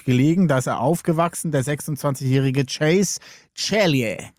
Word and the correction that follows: Ceglie: Man schreibt das C-E-G-L-I-E. – Hast du einen Italiener? – Anscheinend schreibt man es italienisch aus. Cellie. – Cellie. – Ja, Cellie. Chase Cellie.